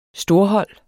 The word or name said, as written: storhold